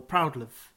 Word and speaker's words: Proudlove: Is it pronounced Proudlove or Proudlove? – Yeah, it's Proudlove.